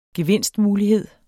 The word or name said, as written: gevinstmulighed